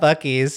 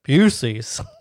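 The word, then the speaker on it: Buc-ee's: I think it was in a Florida one, and they said, how do you pronounce this when you're not in Florida? Busey's.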